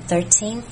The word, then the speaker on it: 13th: 再来 in the 13th century, 13th 是 3th 要怎么念，就是 13 念完接 th 的声音 13th century,